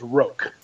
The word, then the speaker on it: Roak: The one that's out now is Roke. – That's right. – It's, you know, R-O-A-K, you know, rhymes with oak tree, Roke.